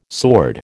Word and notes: sword: American English

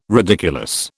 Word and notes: ridiculous: British English

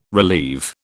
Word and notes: relieve: British English

